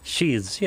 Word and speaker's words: sheaths: But these five sheets, am I saying it right? – Sheaths, yeah. Oh, yeah, sheaths. – Sheaths, yep.